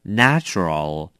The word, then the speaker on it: natural: 这边我也要提醒大家一下它的发音，我们知道原来是来自大自然，这个字的英文叫做 nature, 如果是 nature 的话你就要念 a nature, 可是这边是 natural 就要念成 a, 所以是不一样的 natural, OK, 要分清楚咯。